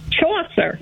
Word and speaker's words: Chaucer: Chaucer.